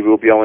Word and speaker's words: Rubio: Anthony Rubio, and it'll direct them to wherever they want to go to find any of my work and my photos.